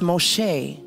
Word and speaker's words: Moshe: In Hebrew, the word Moses or the name Moses is pronounced Moshe.